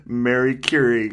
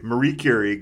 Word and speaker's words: Marie Curie: The one thing that I laughed at was when Mary Curie goes into the... We're saying Mary Curie because that's how they pronounce it in the movie. Marie Curie